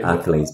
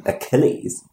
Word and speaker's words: Achilles: Yeah, and that's a nice little example of the English language that I say Achilles, because Kiwis always put the emphasis on the first syllable, and you're like, "What do you mean, Achilles?"